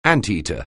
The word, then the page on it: anteater: anglais